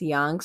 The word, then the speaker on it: Yonge: Club Zanzibar is located on, I believe it's pronounced Yonge Street. That's how the tattoo artist my friend went to go see pronounce it, or somebody pronounced it as Yonge Street.